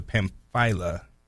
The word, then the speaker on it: Pamphylia: Then they passed through Pisidia and came to Pamphylia, right? Pam- I said that right? Yeah.